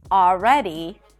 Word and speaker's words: already: The next hack is that you don't have to pronounce the L in the words always, all right, and already. It's not a mistake to pronounce the L, but if you're struggling with the L, just drop those sounds. Simply pronounce an ah sound right before.